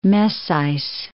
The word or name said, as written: Messys